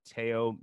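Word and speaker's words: Théo: I'm going to go with Teo Maledon. And you're going to have to look that up. It's spelled T-H-O with a little accent, Maledon.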